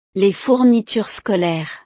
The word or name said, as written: les fournitures scolaires